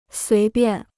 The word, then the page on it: 随便: suí biàn